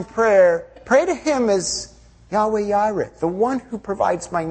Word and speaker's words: Yahweh Yireh: And Yahweh Yireh, or it's often pronounced Jehovah Jireh, but Yahweh Yireh is the way it would be said in Hebrew.